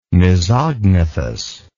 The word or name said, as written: mesognathous